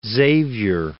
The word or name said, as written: Xavier